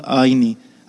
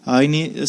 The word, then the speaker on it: Ayni: a página é Cidade Escola Aine. Aine se escreve com A-Y-N-I.